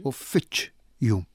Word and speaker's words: uffiċċju: Sawa, issa, jekk aħna mmorru għall-uffiċċju, nindunaw illi l-aċċent qiegħed fis-sillaba tan-nofs iċċ. Uffiċċju.